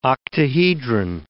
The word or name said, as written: octahedron